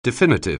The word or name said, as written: definitive